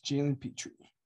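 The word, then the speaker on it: Jalen Pitre: We're looking at Jalen Petrie. Did I pronounce that right? – Yes, Jalen Petrie.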